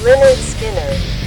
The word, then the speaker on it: Lynyrd Skynyrd: Lynyrd Skynyrd.